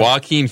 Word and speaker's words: Joaquin: Wow, can you not appreciate the artistic integrity of this film, the depth of the story and rawness of Jao Quinn, how do you say that? – Joaquin Phoenix.